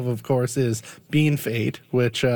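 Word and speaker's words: Bienfait: We have colleagues, you know, who work on the French side here who might come in from out of province and they're shocked that it's not pronounced BFA because that's what it looks like.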